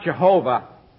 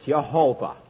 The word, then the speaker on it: Yehovah: It's not Jehovah. It's Yehovah.